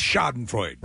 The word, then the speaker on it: Schadenfreude: Schadenfreude? – I think it's Schadenfreude.